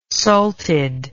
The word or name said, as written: salted